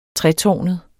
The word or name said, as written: tretårnet